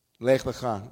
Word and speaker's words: לך לך: Lech lecha, the same word with different vowel pronunciation.